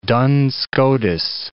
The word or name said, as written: Duns Scotus